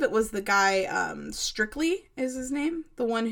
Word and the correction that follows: Strickler: I said his name wrong. – Lon Strickler, I believe, is the one who believes that there are at least or as many as 3 different flying figures.